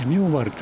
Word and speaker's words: 女原: ここは福岡市西区の明原という、女原と書いて明原と言いますけども、いうところに来ています。